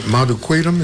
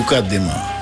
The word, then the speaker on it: Mukaddima: Madukwetum, is that how you pronounce that? – No, Mukaddima.